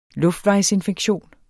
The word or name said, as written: luftvejsinfektion